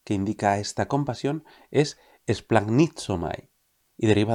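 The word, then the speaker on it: esplagnizomai (σπλαγχνίζομαι): Los evangelios están escritos en griego antiguo, y el verbo griego que se traduce habitualmente por compadecerse, conmoverse o apiadarse es esplagnizomai. Te lo repito por si no te ha quedado claro. Esplagnizomai.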